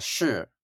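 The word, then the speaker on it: shi (勢): So this idea, and I'm going to pronounce it in the Chinese, but it's shi.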